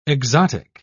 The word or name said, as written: exotic